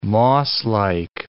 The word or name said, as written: mosslike